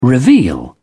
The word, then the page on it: reveal: British English